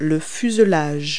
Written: fuselage

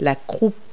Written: croup

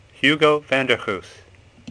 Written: Goes

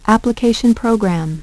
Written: application program

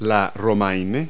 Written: romaine

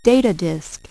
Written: data disk

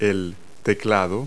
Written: keyboard

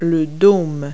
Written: dome

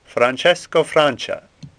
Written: Francia

